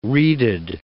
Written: reeded

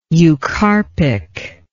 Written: eucarpic

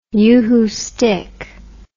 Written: uhu stick

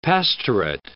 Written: pastorate

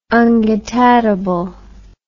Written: ungetatable

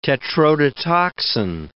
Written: tetrodotoxin

